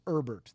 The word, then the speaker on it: Herbert: It's Herbert. The H Herbert. Is silent.